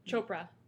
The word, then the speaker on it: Chopra: I read Deepak Chopra or Chopak. Is that how you say his name? – Chopra.